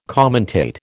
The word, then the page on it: commentate: American English